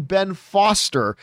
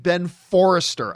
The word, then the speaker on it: Ben Foster: I don't know if I was thinking of Finding Forrester in the back of my mind, but instead of saying Ben Foster, I kept pronouncing it Ben Forrester.